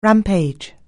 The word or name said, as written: rampage